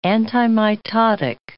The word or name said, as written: antimitotic